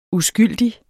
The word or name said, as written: uskyldig